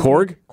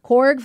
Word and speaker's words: Korg: Because my thing is like I'm just picturing, was it Krog? I always forget how to say it. – Korg? – Korg from from the last Thor movie.